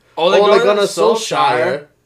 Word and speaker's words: Ole Gunnar Solskjaer: And we forgot to mention Ole Gunnar Solskjaer.